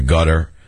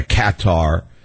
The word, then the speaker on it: Qatar: No one knows how to pronounce that country, gutter. They used to say gutter. Then they said, you can't call a country gutter. So they're Qatar.